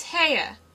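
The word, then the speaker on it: Haigha: His name is Heia." – He pronounced it so as to rhyme with mare.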